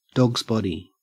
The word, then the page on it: dogsbody: Australian English